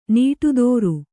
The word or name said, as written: ನಡುದಾರಿ